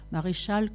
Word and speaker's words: Maréchal: Tel par exemple Sylvain Maréchal, Maréchal comme ça se prononce, comme un maréchal ferrant, un libre penseur.